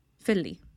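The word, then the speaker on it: Filli: So there's one of my favorite ones that just opened up is called Philly. And it's a Dubai-based chain. Philly? – How do you spell that? Philly? – It's F-I-L-L-I. Philly.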